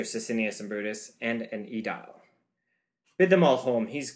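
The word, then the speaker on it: Aedile: All right, Edil. That's the pronunciation now.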